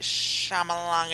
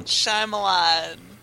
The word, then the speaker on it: Shyamalan: Night Shyamalan and Ding Dong. – That's not how you say it. – Shyamalan.